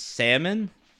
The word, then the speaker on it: salmon: But if you're saying it like that, if it's Salmon, the L silent, because the L, you're not saying the L. It's Salmon. – You're saying it like it's spelled S-A-M-O-N, Salmon.